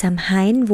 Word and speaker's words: Samhain: Genau, also dieses Samhain wurde schon vor über 2000 Jahren gefeiert, in Irland, Schottland und in anderen keltischen Regionen Europas. Ach ja, und ursprünglich ausgesprochen ist es Samhain. Genau, das Samhain ist eher eben ein deutsches Wort, eben dafür eine deutsche Übersetzung.